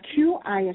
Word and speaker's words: Qi: So QI is pronounced Qi. It's a new spelling.